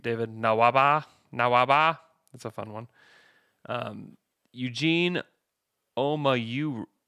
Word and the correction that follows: Omoruyi: Omaru. Oh my gosh. I am dying. Omoruyi. Omoruyi. – Wow. – Omoruyi. Eugene Omoruyi.